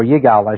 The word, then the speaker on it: Yigal: Yigal Yadin, or Yigal, I should say, is pronounced his name properly, was, until his death, probably the leading or one of the leading Israeli archaeologists.